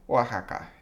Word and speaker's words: Oaxaca: Oaxaca. – Did I say that correctly? – Oaxaca.